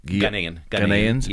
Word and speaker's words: Ghanaians: Naturalized, is it Guyanans? How do you pronounce it? – Ghanaians. Yeah.